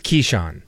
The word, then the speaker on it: Keyshawn: But Keyshawn, is it Keyshawn or Keyshawn? – I think it's Keyshawn. That's how it's pronounced during the games. Keyshawn.